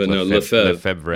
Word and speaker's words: Lefebvre: Lefebvre.